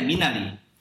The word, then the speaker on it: Minari: Well, is it Minari? Is that how you say it? – Yeah, Minari. – Minari. – But Minari is totally fine. – Okay. Minari, yes.